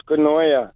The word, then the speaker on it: Koinonia: Yes, it's called Koinonia. – Koinonia. – Yeah, Koinonia. It's a Greek word.